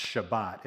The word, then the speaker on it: Shabbat: Sabbath actually has two unique but related meanings in the original Hebrew, which would be pronounced Shabbat.